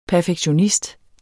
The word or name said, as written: perfektionist